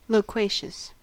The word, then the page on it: loquacious: American English